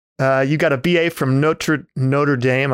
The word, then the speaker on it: Notre Dame: You know, I heard one of the former presidents of Notre Dame say the first part is the French pronunciation. The second part is anglicized. So he said Notre Dame.